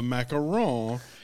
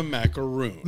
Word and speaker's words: macaron: In a movie that acknowledges that Milk Bar exists, I can't believe that three times in a row they pronounce the cookie that is called a macaron as a macaroon.